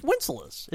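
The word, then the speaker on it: Wenceslas: You say Winceslas, right? – It's Winceslas. It's good King Winceslas.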